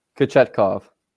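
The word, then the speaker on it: Kochetkov: How do you pronounce his name? Kachayev? – Kachetkov. – Way off.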